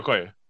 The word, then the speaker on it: Okoye: Uh, is that how you say it? That's it, right? The leader of the uh, Dora Milaje. Yeah. Yeah, Okoye. Okay.